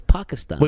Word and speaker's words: Pakistan: I used to go Pakistan. – It's Pakistan. – We went from Texas, Pakistan. – That's right. – Now we're like Pakistan. – Well, he probably. – How do you say? – He's probably right. Pakistan. – Yeah, oh yeah, he is right.